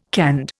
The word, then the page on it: canned: Indian